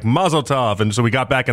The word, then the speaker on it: mazel tov: So it's supposed to be like a almost like a rhymes with love at the back end of it, like a tov type thing, like a mazel tov.